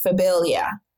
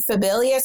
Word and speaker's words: Phabillia: Just a fun fact about that is because, you know, when people can say my first name is Phabillia. – Yeah. – So they would have trouble saying my name, Phabillia.